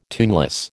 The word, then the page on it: tuneless: American English